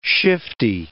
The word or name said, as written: shifty